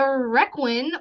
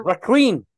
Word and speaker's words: requin: I have the pronunciation from this is straight from Wik- Wiki. – Wikipedia. Ooh, Wiki. – Uh, have a listen, dear listener. Un requin. Un uh, requin a working.